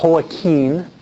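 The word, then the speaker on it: Joaquín: It's pronounced Joachim. Spanish called Joaquin. And Joachim is the way we say it in English.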